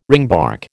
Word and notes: ringbark: American English

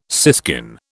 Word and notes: siskin: British English

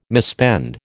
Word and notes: misspend: American English

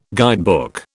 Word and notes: guidebook: British English

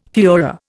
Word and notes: fuehrer: Indian

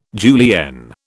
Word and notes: julienne: British English

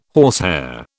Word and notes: horsehair: British English

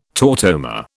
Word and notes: tautomer: British English

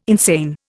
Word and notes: insane: Indian

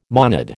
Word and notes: monad: American English

